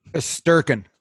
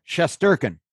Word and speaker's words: Shesterkin: Sturken. – What is it? – There you go. Good start. – Shesterkin.